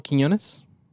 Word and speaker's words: Quiñones: Adolfo Quiñones? – There you go.